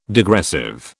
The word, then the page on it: degressive: British English